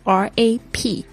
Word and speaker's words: wrap: Gift 就是礼物，wrap W R A P W R A P，特别注意一下这个单词，W 不发音，发 rap。